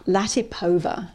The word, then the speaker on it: Latipova: How do you say her name? Latipova.